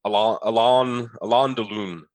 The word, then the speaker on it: Alain Delon: And it's Alain Delon, is how you pronounce it, but has a 95% from critics and a 92% from audiences.